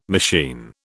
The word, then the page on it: machine: British English